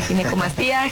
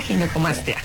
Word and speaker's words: ginecomastia: Pues muchas gracias, y sí, definitivamente creo que es una, para empezar la palabra, le decía, ¿cómo se pronuncia? ¿Ginecomastia? Ginecomastia. – Exacto, ginecomastia.